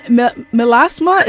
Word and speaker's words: melasma: So that brings us to obviously skin pigmentation and melasma. Is that how it's pronounced? – Yeah.